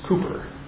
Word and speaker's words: Cowper: It's spelled Cowper, but I understand it's Cooper.